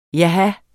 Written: jaha

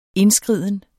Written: indskriden

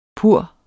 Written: pur

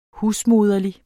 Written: husmoderlig

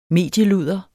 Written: medieludder

